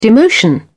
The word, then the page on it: demotion: British English